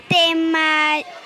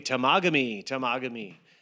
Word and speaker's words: Temagami: Temajami. – Temagami. – Temagami. – Temagami. – Temagami. – Okay, tamagami. That's not quite how they were saying it.